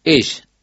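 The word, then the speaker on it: Esh (אש): La palabra fuego en el idioma hebreo es, o se escribe o se dice, Esh, E-S-H. La palabra para decir hombre es I-S-H, Ish.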